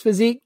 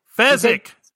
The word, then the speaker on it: Fezzik: chokes Physique into unconsciousness, and tricks Vizzini, did I say it right? I don't care, into drinking poison, killing him. – Fezzik!